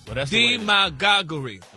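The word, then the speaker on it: demagoguery: Demagoguri. – That's the way the dictionary broke it down. – The pronunciation, like the emphasis, is on the D.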